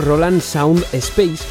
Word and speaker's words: Roland Sound Space: Otro ejemplo lo encontramos en el sistema de audio Roland Sound Space, que creo que antes lo dije mal, es Roland Sound Space o RSS,